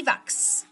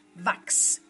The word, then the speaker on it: Wachs: Y vemos que la pronunciación es Vax, que suena como una KS o una CS en español.